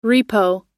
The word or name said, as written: repo